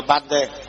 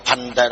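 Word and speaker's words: Bhante: Bante là gì? Đọc là Bante. Còn không biết mấy thầy ra học là Phành Tê, đọc Phành Tê, Phành Tê là thầy không hiểu.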